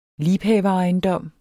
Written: liebhaverejendom

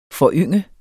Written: FORYNGET

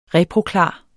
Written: reproklar

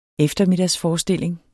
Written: eftermiddagsforestilling

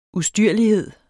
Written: ustyrlighed

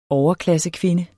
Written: overklassekvinde

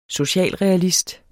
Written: socialrealist

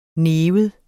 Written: NIV

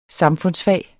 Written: samfundsfag

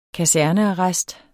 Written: kasernearrest

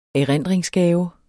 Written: erindringsgave

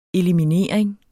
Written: eliminering

